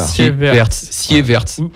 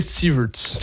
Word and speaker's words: Sieverts: Alors ça s'écrit S-I-E-V-E-R-T-S. – Voilà. – Sievertz. – En anglais, Sievertz. – Sievertz. Voilà. Il va manquer des lettres. Là. – Non, c'est Sievertz.